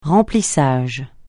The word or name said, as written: remplissage